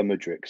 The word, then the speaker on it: Mudryk: Yeah, Mykhailo Mudryk. So in terms of, just forget about the K in his first name, and Mudryk's perfect.